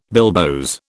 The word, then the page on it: bilboes: British English